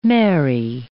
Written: Mary

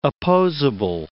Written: opposable

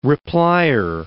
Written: replier

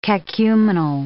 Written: cacuminal

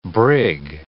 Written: brig

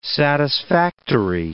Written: satisfactory

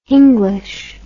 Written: hinglish

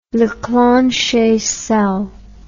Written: leclanche cell

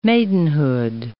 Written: maidenhood

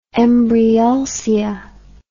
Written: embryulcia

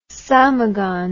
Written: samogon

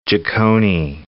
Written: Giacconi

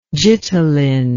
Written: gitalin

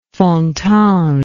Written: fontange

